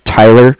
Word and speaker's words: Tyler: Okay, so my name is Tyler, T-Y-L-E-R, and I'm calling with Cardiff. It's spelled C-A-R-D-I-F-F, as in Frank Frank.